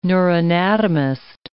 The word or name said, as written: neuroanatomist